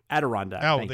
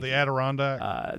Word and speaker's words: Adirondack: I'd never say this right. That's the Adri Onak. How do you know what I'm talking about? The mountains, the Adirondack. – Adirondack.